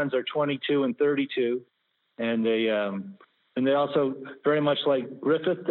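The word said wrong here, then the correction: Gryffin: Did I say that right? – Griffin, I think it's G-R-Y, and how do you spell it? Let me look. It's G-R-Y-F-F-I-N.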